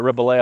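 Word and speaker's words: Rebillet: which is this Mark Ribollet, or Mark Ribollet is how it's spelled, but Ribollet, I believe, is how he pronounces it.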